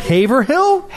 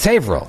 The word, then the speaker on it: Haverhill: It's spelled Haverhill? – Yes, but no one says that. It's Haverhill.